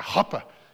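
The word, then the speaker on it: chuppah: I think you have to almost pronounce it with like a German growl in your throat to say it right, but like a huppa is what it was called.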